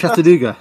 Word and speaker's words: Chattanooga: How do you say that? – Chattanooga. – Yeah, there you go.